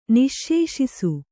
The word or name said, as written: ನಿಶ್ಶೇಷಿಸು